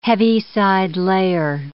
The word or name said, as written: Heaviside layer